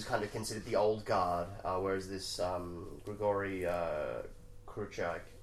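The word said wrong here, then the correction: Chukhrai: super hard to pronounce. Yeah. Chukrai. Grigori Chukrai is kind of considered, like, the young pup.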